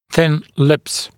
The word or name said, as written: thin lips